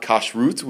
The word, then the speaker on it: kashrut: The Laws of Kashrus, not Kashrut, which is how, modern Hebrew speakers would and conservative Jews would pronounce it, but Kashrus is the way traditional Ashkenazi Jews would have pronounced the word kashrut, which is the system of keeping kosher. And so people in the Orthodox world today, in the Ashkenazi Orthodox world, would pronounce it kashrus.